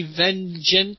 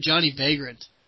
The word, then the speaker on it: Vengent: I used to call him Johnny Vagrant. – Yeah, I know he did. – Can you believe that? There's no R in it.